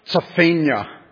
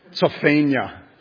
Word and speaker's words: Zephaniah: My guess is that if we were ancient Hebrew speakers, we would say his name something like Zephaniah. Zephaniah, almost like the end of Pennsylvania.